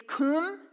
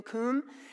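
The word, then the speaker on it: kum (קום): It's kum. It's kind of like zoom, like rhymes with zoom, kum.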